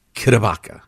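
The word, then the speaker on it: CdeBaca: It's spelled C, small D-E, capital B-A-C-A. Kudabaka.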